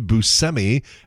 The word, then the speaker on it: Buscemi: Steve Buscemi. And that turns out is the correct pronunciation. A lot of us have been saying it wrong all these years.